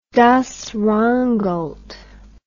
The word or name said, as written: rheingold das